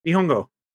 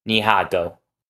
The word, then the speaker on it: Nihongo: Nihongo. – Oh. – Yeah. – Nihongo. – Nihongo. N-A-N-I-H-O Geo. – Nihago.